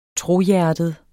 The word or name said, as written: trohjertet